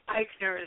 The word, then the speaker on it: Ikner: That last name, Eichner, is so hard to spell. Could you tell them? – Eichner is spelled I-K-N-E-R, and actually Kaylin might be kind of difficult to spell, too.